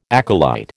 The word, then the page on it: acolyte: American English